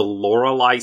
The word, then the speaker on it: Loreley: Loreley Splendor Helm Nice. – Spelled the same way I spell my name, L O R E L E Y, the, the good old fashioned German way.